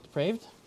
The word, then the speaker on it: depraved: God has turned you over to a deprived mind. Depraved? Did I say that right? Yeah, that word.